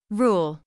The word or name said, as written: rule